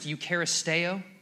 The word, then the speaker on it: eucharisteo: It's pronounced eucharisteo.